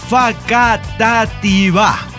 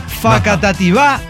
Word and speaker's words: Facatativá: Facatativa. Con acento en la última sílaba. – Ah, mire. – Facatativá, Colombia.